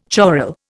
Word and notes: choral: Indian

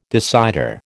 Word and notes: decider: American English

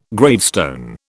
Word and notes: gravestone: British English

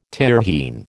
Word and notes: Tyrrhene: American English

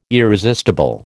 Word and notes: irresistible: American English

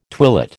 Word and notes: twilit: American English

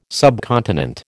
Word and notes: subcontinent: American English

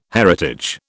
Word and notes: heritage: British English

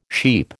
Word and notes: sheep: American English

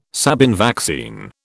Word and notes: Sabin vaccine: British English

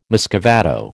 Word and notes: muscovado: American English

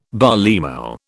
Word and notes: barleymow: British English

